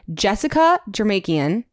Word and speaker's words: Jessica Jermakian: All right, guys, we are so excited because we have the VP of Vehicle Research from the IIHS, Jessica Jermakian.